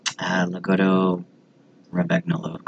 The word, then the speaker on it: Lagotto Romagnolo: This is a Lagotto Romagnolo. Double check my pronunciation of that. – Lagotto Romagnolo. Close enough.